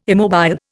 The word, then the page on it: immobile: Indian